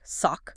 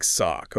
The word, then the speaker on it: SOC: For example, they set up Michigan's first K-12 Security Operations Center, SOC, pronounced like sock. – Like sock, okay.